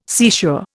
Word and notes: seashore: Indian

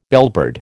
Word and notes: bellbird: American English

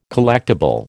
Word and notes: collectible: American English

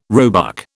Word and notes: roebuck: British English

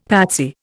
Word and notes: patsy: Indian

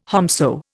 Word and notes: whomso: Indian

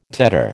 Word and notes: tetter: American English

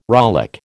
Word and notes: rollick: American English

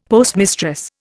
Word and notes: postmistress: Indian

